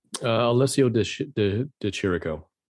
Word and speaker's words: Alessio De Chirico: Is that a Chirico? Because it's Italian. How do you say that? – Alessio De Chirico.